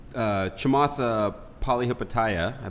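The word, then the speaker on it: Chamath Palihapitiya: Chamatha Palihapitiya, I believe is the way you pronounce his name. – Nice. – Not quite sure. Is that close? – Close, good. Yeah.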